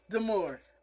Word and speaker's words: D'Amore: It's Scott D'Amore, okay? Me and you have been saying Scott D'Amore for every week. It's pronounced D'Amore.